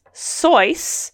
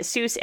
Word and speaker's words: Seuss: And the standard German pronunciation would be something like Zeus. He himself pronounced it initially, at least, as Zeus. We've actually heard that in a Jeopardy clue quite a while back. – Mm-hmm. – A Dartmouth colleague wrote a little rhyme about how he pronounced it. But as he came to be well known under that pen name, people started pronouncing it Seuss, and he eventually accepted and switched to that anglicized pronunciation, saying that it evoked a figure advantageous for an author of children's books to be associated with, Mother Goose.